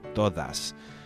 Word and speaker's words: todas: So you have to pronounce that with a Spanish D with your tongue between your teeth. Todo, todas. Okay? It's actually more like the American TH in the word the or this.